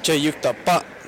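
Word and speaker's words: jeyukdeopbap (제육덮밥): I don't stress anything, or I try to say it like the Korean would say it, which is more of an emphasis on the end, so jeyukdeopbap is kind of how they would say it.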